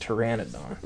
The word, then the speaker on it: pteranodon: Yeah, well, it starts with a P, but I think it's pronounced pteranodon. – Oh. – Yeah, but that's good, though, that you remembered. I didn't remember. I called it what did I call it? I can't even remember now. The pteranodon was soaring through the sky.